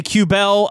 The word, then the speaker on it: Kubel: Demoted on Tuesday, Nicholas Albe, Q-Bell. – How dare you? – I said it right. – That is apparently how it's supposed to be said. Kubel, in my opinion.